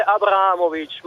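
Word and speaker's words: Abrahamowicz: Guardi, sarebbe Abramovic, ma lei l'ha pronunciato molto bene lo stesso.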